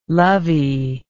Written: lovey

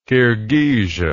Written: kirghizia

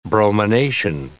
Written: bromination

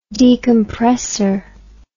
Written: decompressor

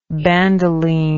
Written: bandoline

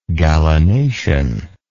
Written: gallinacean

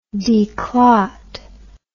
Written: declot

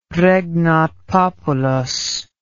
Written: regnat populus

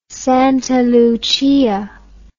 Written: Santa Lucia

